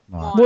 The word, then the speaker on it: Moana: And the next Disney film coming out later this year, how do you pronounce it, Moana? – Moana.